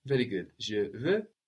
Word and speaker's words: veux: Tu veux.